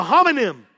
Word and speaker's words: homonym: A homonym. I had my M and N's messed up.